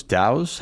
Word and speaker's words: dhow: And then also they have these, they have three dhows. How do you pronounce that? D-H-O-W? – Dhow.